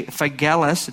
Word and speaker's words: Phygellus: This you know, that all those in Asia have turned away from me, among whom are, how you say, Phygellus, it's a hard G, and Hermogenes.